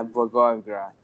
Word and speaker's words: Blagoevgrad: By the way, the place was, I'm going to pronounce it wrong. This is Bulgaria. It was Blagoevgrad? – Yeah, Blagoevgrad. – There you go.